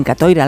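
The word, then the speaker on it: Catoira: precisamente en Katoira, los embarcos de los vikingos por esa zona de Galicia. ¿Lo he dicho bien o me equivoco en algo, Francisco? – No, lo has dicho perfectamente, sí, yo siempre intento rebuscar esos...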